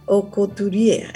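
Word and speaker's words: Aucouturier: Es así, Ocuturier. Es que tú lo pronuncias sí. – Tan lindo. – Así, ay, qué linda, sí. Y yo lo pronuncio. Es que es complicado. Pero mira, es como la couture, la alta costura.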